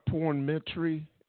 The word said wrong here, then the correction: pornetry: Am I saying it right? – Pornetry. Okay. Right. It's like poetry, but with like a intimate spin.